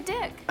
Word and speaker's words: Dique: Mr. Jason Dick. – It's spelled D-I-Q-U-E. It's Dutch, and yes, I've thought about changing it.